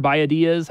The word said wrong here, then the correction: Biadasz: Biadasz, yeah.